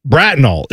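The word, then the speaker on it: Bratenahl: Brattonall is how you say that.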